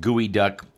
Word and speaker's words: geoduck: They cite the gooey duck. It's written as geoduck, but I guess it's pronounced gooey duck clam.